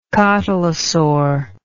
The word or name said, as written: cotylosaurian